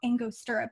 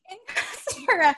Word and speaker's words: Angostura: So I think it's called I've been pronouncing it "ignostra bitters for the longest time, and everyone always looked at me strangely. And I think it's because no one else knew how to pronounce it. And then I was writing it in my book, and I was like, "It's called Angostura bitters."